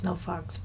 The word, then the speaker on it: NoFX: Well, it's pronounced No Fucks, but NoFX.